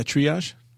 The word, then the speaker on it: triage: Okay, so triage, triage triage. is French. oh, triage. – No, just triage. We've Oh. Americanized it.